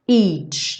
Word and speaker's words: each: Each day. Aqui, each, tem que ter esse som do tchau, do TCH. Each.